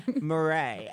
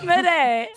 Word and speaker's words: Mirae: M-I-R-A-E, but the R is rolled. – So M-I-R-A-E. – I was like, I can't... – Murray. – But I was like, I can't roll my R, so I don't know if I could say it. He's like, I can't either. The trick is you just say a D sound and it sounds like you're...